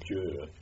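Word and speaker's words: Győr: Gür, is it how you pronounce it? – Gür is the pronunciation of the town I come from.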